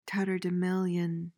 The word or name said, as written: tatterdemalion